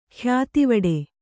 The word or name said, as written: ಖ್ಯಾತಿವಡೆ